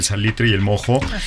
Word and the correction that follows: moho: Y el moho. – El moho, El moho, lo dije mal.